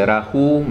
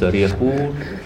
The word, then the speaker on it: Rahu: C'est en sanskrit, c'est Rahu, mais en khmer, ça se prononce Rihu.